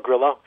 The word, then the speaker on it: Grillo: But another kid from Argentina, Emiliano, is it Grillo or Grillo? How do you pronounce his last name? – You can go Grillo, sure.